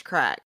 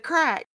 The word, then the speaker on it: craic: To their way of thinking, this was just another example of Irish crack. And that is C-R-A-I-C. And I looked up the definition and the pronunciation, Irish listeners, I swear. And it said crack.